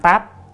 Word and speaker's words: táp: Tam Táp, chữ này đọc là táp nha, không phải đọc là tạp nha.